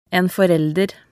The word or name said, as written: en forelder